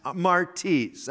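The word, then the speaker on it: martes (μάρτυς): The word for witness in the Greek is the word martes, M-A-R, it would spell it in English anyway, M-A-R-T-E-E-S, martes, that's how it's pronounced. We get our English word martyr. Martyr, M-A-R-T-Y-R, I think is how you spell it.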